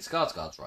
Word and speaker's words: Skarsgård: Stellan Skarsgård. I think Skarsgård's right. That's what sounds. – Skarsgård sounds right.